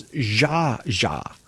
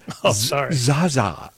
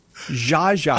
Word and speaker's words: Zsa Zsa: It's Zsa Zsa. You said Zsa Zsa. Well, I don't know what they. This is a major. – It's Zsa. – Yeah, this is a major cultural figure, and you don't even try to say her name correctly.